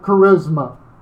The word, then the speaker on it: charisma: That word gift there in the Greek is how we would pronounce it, charisma.